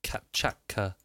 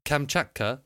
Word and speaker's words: Kamchatka: Based on the real-life Kamchatka Peninsula. Mm. So where is... all right, I'm going to just Google the Kamchatka Peninsula. – You butchered that. – Oh, and what? Kamchatka? – Yeah.